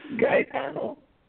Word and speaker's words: Gaetano: Yes, Gaetano.